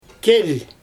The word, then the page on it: kel: Xyzyl